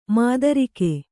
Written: ಮಧುರಿಕೆ

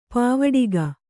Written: ಪಾವಟಿಕೆ

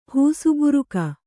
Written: ಹೂಸುಬುರುಕ

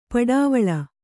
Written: ಪಡವಳಿ

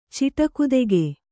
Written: ಚಿಟಕುದೆಗೆ

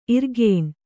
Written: ಇರ್ಗೇಣ್

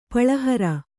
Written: ಪುಳಿಹೀರೆ

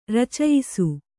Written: ರಚಯಿಸು

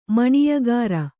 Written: ಮಾನ್ಯಕಾಱ

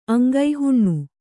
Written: ಅಂಗಹೀನೆ

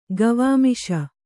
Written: ಗವಾಮಿಷ

